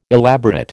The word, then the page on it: elaborate: American English